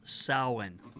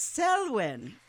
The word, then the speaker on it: Samhain: So the Gaels, or Celtics, believed that it was a time when the walls between our world, excuse me, might I correct your pronunciation? – Oh, yes, please. – It is pronounced Samhain. – Oh, I have a Celt in the room, and it's Samhain.